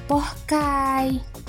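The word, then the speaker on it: poké: Buat rekan Buana yang baru dengar pok, pok itu merupakan hidangan nasional Hawaii dan dapat dinikmati oleh wisatawan muslim nih. Eh, tunggu deh. Pengucapannya yang benar tuh pohkai.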